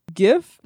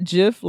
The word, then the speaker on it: GIF: I appreciate you both, but please, it's pronounced GIF, not JIF, like the peanut butter. Just drop the tea off gift and you're good.